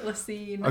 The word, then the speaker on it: La Cygne: he agrees to take him to La Cygne. I think that's how you pronounce it. That's how I pronounced it earlier, right? the first time. Yeah. okay. La Cygne.